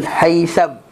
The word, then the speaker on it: Haitham: Abdul Haythan at Abdul Haytham bukan Haythan Abdul Abul Haitham Betul kan buku tu M Bukan N Abul Haitham Bin al Bukan Tayhan Tayhan At-Tayhan Betul kan ejaan T-A-Y-Y-I Y-Y-I Tayyihan Tayyihan Bukan ta tay Tayyihan Sebagai pengganti Rifa'ah bin al Munzir.